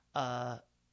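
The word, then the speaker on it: Saul: And and he's great, and he's a very, his name is Sayul, S-A-U-L, I think is how it's spelled.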